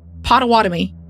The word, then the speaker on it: Pottawatomie: On December 4th, 2015, Liz walked into the, I hate this police station name, Pottawatomie? I'm over it. Listen, when I say police station, it's that one. – Listen, you just need to say the Paw Paw Police Department. – It's Pottawatomie. Pottawatomie.